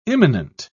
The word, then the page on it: immanent: British English